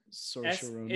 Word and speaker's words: Saoirse Ronan: Saoirse Ronan. – It's spelled like S-A-I-O-R-S-E. – S-A-O-R something.